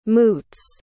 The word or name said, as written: moots